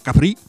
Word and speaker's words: Capri: che era la milanese Maria Capri. – Con l'accento sulla I.